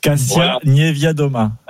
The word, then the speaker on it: Kasia Niewiadoma: Et surtout la bataille pour le général, la polonaise dont je n'arrive pas à prononcer le nom, Kasia Niewiadoma.